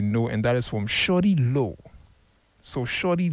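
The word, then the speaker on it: Shawty Lo: But to Prince, if you're doing the listening, the artist's name is Shorty Low. It's Shorty Low. It's spelled S-H-A-W-T-Y.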